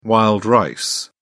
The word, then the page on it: wild rice: British English